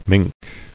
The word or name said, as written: Mink